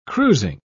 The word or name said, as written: cruising